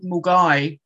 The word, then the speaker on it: Milngavie: So yeah, I mean, I was born in a place called Mulgai. Not born. I wasn't born there. I lived there for the first 4 years of my life. Mulgai is spelled Milngavie.